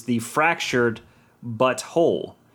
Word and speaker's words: The Fractured But Whole: And I don't remember what the title was, but the title of the second one, just as jab at the censors, is The Fractured But Whole. So it's The Fractured, but then it's spelled B-U-T and then W-H-O-L-E, but you say it together and without context, it's, you know, that's them going, we'll get you.